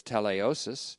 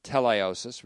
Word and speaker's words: teleiosis: The word here is teleiosis, T E L E I, long O S I S, teleiosis, related to the word tetelestai and other perfection or completion terminology in the New Testament.